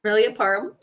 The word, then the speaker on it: Permelia Parham: And you, well, you're pronouncing my name perfectly. It's Permelia Parham.